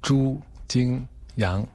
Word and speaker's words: Zhu Jingyang: Eh, la pronunciación correcta es Chu Chin, Yang. Chu Jin Yang.